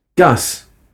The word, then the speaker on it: Gus: Gus. Gus Baker. – Sorry, how do you spell that? Is it G-A-S? – No, it's G-U-S.